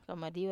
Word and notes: kamadiwa: wamesa

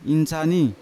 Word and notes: insani: Yawa

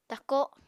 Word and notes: tako: apatani

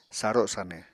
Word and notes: saro sanw: Apatani

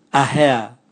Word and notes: ahea: Raga

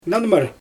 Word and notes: nanmyr: Xyzyl

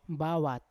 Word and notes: bawat: Yawa

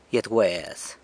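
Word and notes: gyet wez: San Lucas Quiaviní Zapotec

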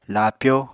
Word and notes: laapyó: Apatani

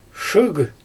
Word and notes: ʃɯɯɣ: Xyzyl

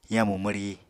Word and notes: yamu mwri: Apatani

